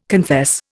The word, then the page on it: confess: Indian